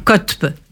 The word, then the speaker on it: Qotb: grands théoriciens s'appelle Saïd Kotb, Copte, non, Kotb, ça s'écrit tantôt Q-O-T-B, euh, tantôt ».